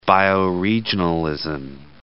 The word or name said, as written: bioregionalism